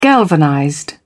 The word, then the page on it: galvanized: British English